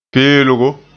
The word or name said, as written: peelgo